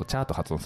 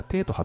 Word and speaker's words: 茶: そうなんですよ、発音の違い、うんうん、で中国のどの地域から伝わったかが分かるんですよ、はい、で広東省ではお茶のことチャーと発音するんですね。 – うーん、で福建省ではテーと発音するんですよ、